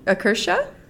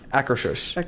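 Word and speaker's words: Akershus: In Norway, we have Can I pronounce that one? – Akersha? – Akershush.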